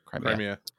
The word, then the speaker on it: Crimea: Crimea.